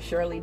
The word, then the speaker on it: Sherley: You guys can actually find me on YouTube under Shirley Delma. That's my name, S-H-E-R-L-E-Y. Delma is spelled D-E-L-M-A.